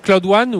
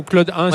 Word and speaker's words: Cloud One: Alors, ce que vous présentez, c'est Cloud One ou Cloud 1, si je prononce en français.